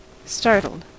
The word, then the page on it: startled: American English